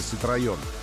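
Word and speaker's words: Citroën: Ё с точками. Как Ситроен. Вот так. Правильно произносится Ситроен.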